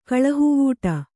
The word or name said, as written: ಕಳಹುವೂಟ